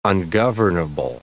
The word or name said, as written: ungovernable